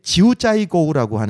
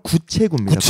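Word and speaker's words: 九寨沟: 꾸이린이 굉장히 또 아름답고 그리고 이게 지우짜이거우라고 하는데 한국어 발음 구체구입니다.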